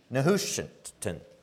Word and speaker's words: Nehushtan: Nehushtan.